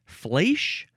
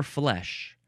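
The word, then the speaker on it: flèche: The first word in this episode is pronounced either flesh or flesh. Flesh or flesh. It is spelled F-L-E-C-H-E.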